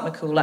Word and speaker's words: MacCumhaill: It's spelt M-A-C-C-U-M-H-A-I-L-L. But that is spelt McCool, actually. – But M-H is like a W. – In Irish, yeah.